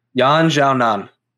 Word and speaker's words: Yan Xiaonan: How do we say her name again? – Yan Xiaonan. – Yan Xiaonan.